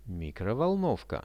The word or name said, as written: mikrovolnovka